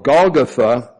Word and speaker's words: Golgotha: And they came to a place called Golgotha. That's the way you pronounce it in Aramaic.